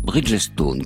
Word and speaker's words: Bridgestone: Le 16 septembre dernier, Bridgestone, que nous prononcerons Bridgestone, comme le font les salariés de cette entreprise,